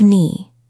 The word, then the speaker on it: knee: Think of knee. It has a silent K.